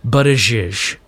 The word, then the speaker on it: Buttigieg: The mayor of South Bend, the guy with the strange name, Pete, it's spelled Buttigieg, but it is pronounced Buttigieg.